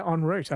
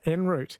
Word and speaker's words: en route: I do say en route. I don't say en route. I go a bit more French with that one.